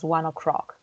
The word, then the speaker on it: One Ok Rock: So yeah, they are spelled like One Ok, OK Rock, but it's pronounced One Ok Rock, like 1 o'clock.